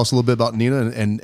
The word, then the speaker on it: Neenah: We have a team from Wisconsin, Neenah, Wisconsin.